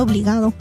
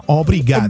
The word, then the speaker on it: obrigado: Porque van a pensar que estás diciendo obligado. – No, obrigado.